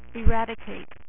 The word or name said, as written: eradicator